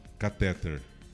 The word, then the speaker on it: catéter: A pronúncia correta é cateter ou catéter? Esse que você põe no coração, do coração, hein? – Catéter. – Catéter. – Não. Catéter, todo mundo fala catéter.